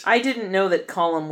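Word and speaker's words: Colm: Fucking Colum? – I know!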